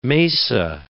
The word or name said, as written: mesa